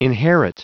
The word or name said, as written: inherit